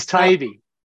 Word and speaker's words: Taibbi: I've heard Taibbi. I've heard all kinds. – But it's Taibbi.